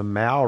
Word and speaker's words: Maow: Of course, it's spelled M-A-O-W, not Mao as in Chairman Mao, but Mao as in, I guess, the cat Mao.